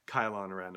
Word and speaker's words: Kylo Ren: Like, rhymes with Cylon, Kylo Ren.